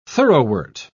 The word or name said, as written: thoroughwort